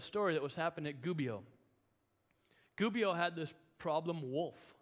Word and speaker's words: Gubbio: And I said Gubbio because I looked up how to pronounce it online. And there was an Italian guy saying Gubbio.